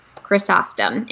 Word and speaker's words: Chrysostom: So it's spelled just like it sounds, but with a Y. So Chrysostom. Chrysostom. And cat is with a K, K-A-T. – K-A-T. And Benefab's is B-E-N-E-F-A-B.